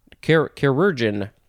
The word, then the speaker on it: chirurgeon: Chirurgeon. Is that how you say it? It's sort of like surgeon, but we started with a chir, C-H-I-R. So it's a chirurgeon.